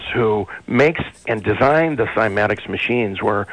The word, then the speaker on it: cymatics: Early experiments were done in cymatics or kymatics. The Greek pronunciation also allows you to say kymatics as well.